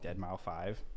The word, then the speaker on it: Deadmau5: Deadmau5.